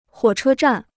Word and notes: 火车站: huǒ chē zhàn

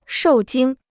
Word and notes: 受惊: shòu jīng

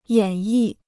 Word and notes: 演绎: yǎn yì

